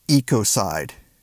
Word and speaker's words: ecocide: The word is ecocide. They will. They, you notice it rhymes with genocide?